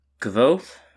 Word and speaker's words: Kvothe: um, and it is uh, the, the series is about this man named Kvothe. Mm-hmm. pronounced like Quoth almost, is a line in the book, um, who is sort of relaying his life's story to um, a guy named Chronicler.